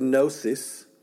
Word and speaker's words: gnosis: It comes from the Greek word gnosis, G-N-O-S-I-S. The G is silent.